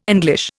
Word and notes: English: Indian

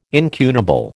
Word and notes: incunable: American English

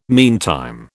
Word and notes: meantime: British English

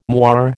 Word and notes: moire: American English

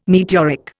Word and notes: meteoric: Indian

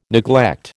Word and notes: neglect: American English

